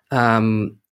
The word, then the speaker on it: Cerpelloni: Because do you want the Italian pronunciation or the English pronunciation? So sometimes I say Cerpelloni, which doesn't, you know, I don't identify with that. Cerpelloni, that's the Italian right pronunciation.